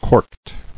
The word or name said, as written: Corked